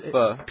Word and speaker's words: pho: Not, pho. Pho is how it's spelled, so you want to say pho, but it's not.